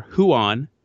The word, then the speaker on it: Ruan: They brought in a kid by the name of Ruan, or Huan, H-U-A-N is the pronunciation, R-U-A-N is the spelling.